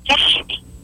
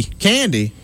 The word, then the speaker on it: Candy: No, Candy. – Candy? – Candy. Yeah, you know, like peppermint candy.